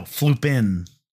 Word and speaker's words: flooping: No, flooping. – Flooping, sorry. – Yeah, that's like my mistake. – Rhymes with looping.